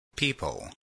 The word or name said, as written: people